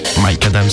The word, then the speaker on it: Micadam: Mike Adam, c'est comme ça que vous prononcez. Mike Adam, si si.